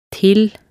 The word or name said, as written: til